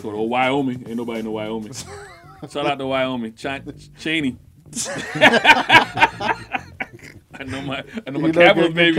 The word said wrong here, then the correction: Cheyenne: NHL I think it's pronounced Cheyenne. Look like Cheney to me. – It's Cheyenne. You had to remember how to say it. – Somebody from Wyoming called. Is it Cheyenne or is it Cheney? – It's Cheyenne.